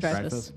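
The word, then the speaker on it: Dreyfuss: Dreyfus.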